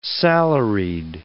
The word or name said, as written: salaried